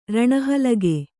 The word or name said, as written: ರಣಹಲಗೆ